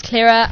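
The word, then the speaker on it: Claira: It's Clara underscore Taverner, so it's C-L-A-I-R-A underscore T-A-V-E-N-E-R.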